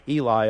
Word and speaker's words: Ely: There's a lot of Elys. Sometimes they pronounce it Eli up in this area.